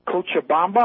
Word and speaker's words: Cochabamba: And I'm not saying it's the total barometer, but they've had 2,000 of them, and most of them went to that city, Cochabamba.